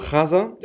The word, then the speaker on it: Haza: Und er hat äh, mir den Conta, äh, Condado de Haza, mhm. spricht man das so? Haza? Ich habe keine, ich kann kein Spanisch. Ja, genau.